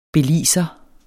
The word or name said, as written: belizer